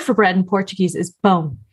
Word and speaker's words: pão: And this word is not the easiest to pronounce for all non-native speakers. And I was reading a sentence with the word pão, and I forgot to make the very necessary nasal sound.